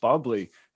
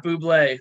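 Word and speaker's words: Bubly: It's not like those other pieces of shit uh, assholes that make soda waters, but Bubbly is a fucking brand you can trust. What were you saying? Bubbly. It's pronounced bubbly, not buble, like the commercials.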